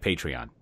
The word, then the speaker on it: Patreon: So, if you go, it's Patreon, which I don't, it's spelled really weird, but it's like Patreon. It's a weird word.